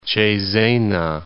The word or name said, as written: Cesena